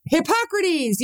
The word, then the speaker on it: Hippocrates: I never say his name right. Hippocrates? – I know, I just know Hippocratic. Hippocrates? Whatever. – Hippocrates, yes. Yeah.